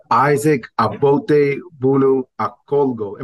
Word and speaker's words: Isaac Abotebuno Akolgo: Today, we are really excited to be joined by Isaac Abotebuno Akolgo.